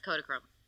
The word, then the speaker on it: Kodachrome: And then Kodachrome is, am I saying that right? Kodachrome? – Yes, Kodachrome.